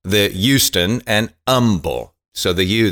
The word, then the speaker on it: Humble: Uh, and this is from uh, this is from Humble, Texas. Uh, just like Houston, you don't pronounce the H.